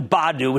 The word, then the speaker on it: Badoo: Now, this would be an easier call, frankly, if it were just Bumble without the Badoo, which, by the way, is pronounced like Scooby Doo, Badoo baggage.